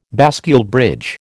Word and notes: bascule bridge: American English

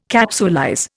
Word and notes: capsulize: Indian

